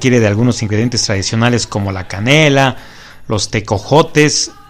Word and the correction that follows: tejocotes: Sí lo dije bien, tejocotes, ¿verdad? Tejocotes. Está perfecto esto, ¿verdad?